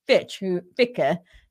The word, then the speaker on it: Fichte: It was actually John Gottlieb Fichte is how it's pronounced, who talked about that.